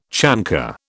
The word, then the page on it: chancre: British English